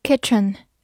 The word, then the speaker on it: kitchen: Kitchen, K I T C H E N, kitchen, K I 发 k t 不发音，c h 发 ch，e n 发 n，kitchen kitchen，它是一个名词，表示厨房。